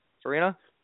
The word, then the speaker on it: Farina: Farina.